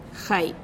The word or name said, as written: jai